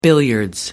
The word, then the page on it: billiards: American English